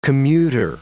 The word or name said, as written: commuter